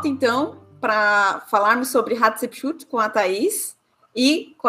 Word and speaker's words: Hatshepsut: Isso mesmo, Hatshepsut.